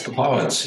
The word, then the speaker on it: Papuans: Yes, Papuans, yes.